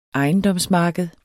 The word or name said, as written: ejendomsmarked